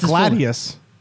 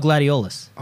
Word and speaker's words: Gladiolus: You're going to get into the shoes of Gladiolus. – Oh, yeah. – And do a little story. – The trailer looks really good. – His name's Gladiolus? I thought it was Gladius. – No, Gladiolus.